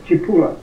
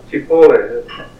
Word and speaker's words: Cipolla: Is that how you pronounce the word, Saipol? – The right way to pronounce it is Cipolla. – Cipolla. – That's Italian.